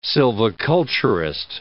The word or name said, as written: silviculturist